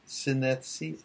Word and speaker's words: synesthesia: Synes- synesthesia. Synesthesia.